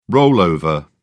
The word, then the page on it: rollover: British English